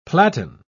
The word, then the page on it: platan: British English